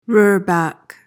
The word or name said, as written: roorback